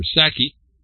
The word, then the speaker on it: Psaki: President Joe Biden's press secretary Jen Psaki, or Psaki, if the P is silent, worked for an Israeli firm accused of involvement of surveillance of Palestinians under Israeli military occupation.